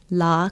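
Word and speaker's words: Lā: Today is Christmas Day, and our Hawaiian word for today is la kalikimaka, Christmas Day. La is spelled L-A with a makron or kahako over the vowel, meaning day.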